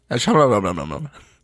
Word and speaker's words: Shyamalan: Night Shyamalan? – Yeah. Shyamalan.